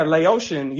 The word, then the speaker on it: Laotian: Yeah, Laotian.